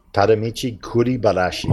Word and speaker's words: Tadamichi Kuribayashi: Tadamichi Kuribayashi. – Well, at least I'm confident that you pronounced that perfectly.